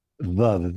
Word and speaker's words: Vav: Vav, VAV is the way to spell it in English.